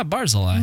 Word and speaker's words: Barzillai: It's a Barzillai, if I'm saying that right. – Oh yeah, Barzillai.